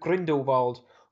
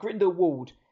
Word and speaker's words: Grindelwald: The Crimes of Grindelwald, depending on how you say it, whether you're Jude Law or Johnny Depp, there's different ways to say it, but Grindelwald.